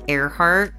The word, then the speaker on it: Ehrhardt: No, they can find me on my Twitter at Shell Earhart, spelled like Michelle, but with the M-I cut off. And the last name is E-H-R-H-A-R-D-T.